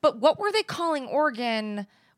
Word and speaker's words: Oregon: Oregon. Like oregano.